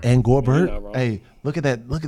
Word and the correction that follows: Gobert: Rudy Gobert.